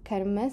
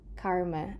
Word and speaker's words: Karma: Notice that this is pronounced Karma, sort of with a closed A, and not Karma, as we usually tend to hear often spoken in mainstream media.